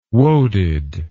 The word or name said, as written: woaded